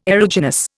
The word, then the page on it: aeruginous: Indian